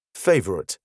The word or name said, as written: favourite